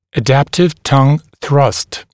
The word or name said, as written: adaptive tongue thrust